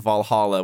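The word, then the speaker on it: VA-11 HALL-A: Valhalla, which is spelled V-A-11 Hall A, because it's cyberpunk.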